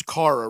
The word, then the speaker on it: Chara: It's Kara, really. It's spelled with a C-H, but it's a K-H pronunciation.